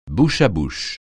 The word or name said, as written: bouche-à-bouche